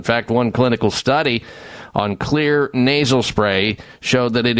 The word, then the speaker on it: Xlear: Crack out the Clear Nasal Spray. Clear is spelled X-L-E-A-R because the X stands for that one of the magic ingredients in there, the main one, xylitol.